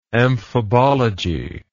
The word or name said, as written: amphibologically